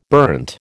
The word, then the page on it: burnt: American English